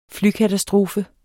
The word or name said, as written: flykatastrofe